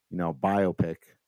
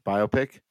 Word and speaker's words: biopic: Biopic? – Biopic? But I have heard folks say biopic, and I'm like, no, it's not. I'm not going to go see a pic.